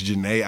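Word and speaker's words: Jhené: I saw Marsha Ambrosia, and I didn't get to see Janine Aiko. I say her name so wrong. – Yeah, I know. – Name is Janae Aiko? – Yeah. – Okay. I'd be calling that motherfucker Janine.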